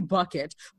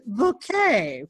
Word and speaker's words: Bucket: It's really bucket, but she says it's pronounced bouquet.